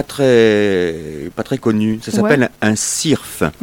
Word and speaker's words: syrphe: Eh bien voilà, syrphe. Ça s'écrit S-Y-R-P-H-E.